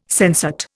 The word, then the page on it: sensate: Indian